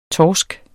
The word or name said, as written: torsk